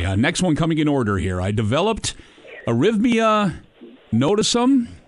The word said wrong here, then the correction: erythema nodosum: I'm not sure if I said that pronounced. – Erythema nodosum. Erythema nodosum. Yeah.